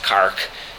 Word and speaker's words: Cork: I was in a pub in Cork, Ireland, pronounced Cark.